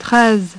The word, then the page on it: treize: French